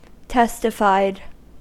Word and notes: testified: American English

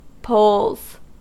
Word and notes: poles: American English

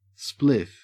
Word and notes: spliff: Australian English

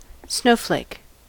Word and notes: snowflake: American English

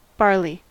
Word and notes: barley: American English

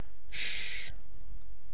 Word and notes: shh: British English

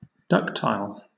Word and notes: ductile: British English

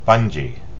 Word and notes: bungee: American English

